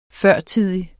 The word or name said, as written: førtidig